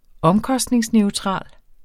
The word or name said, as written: omkostningsneutral